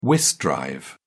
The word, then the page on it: whist drive: British English